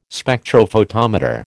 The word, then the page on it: Spectrophotometer: American English